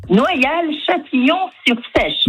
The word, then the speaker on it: Noyal-Châtillon-sur-Seiche: Noyal-Châtillon-sur-Seiche.